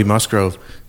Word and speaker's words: Musgrove: No, it's actually Cody Musgrove, son and descendant of Sylvia.